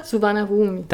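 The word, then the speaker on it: Suvarnabhumi: Und es war ja noch der alte, also nicht der heutige Suvin Bam Bum Bum, den kann ich leider nie aussprechen. Wie nochmal? – Suvana Rumi. – Danke, genau der.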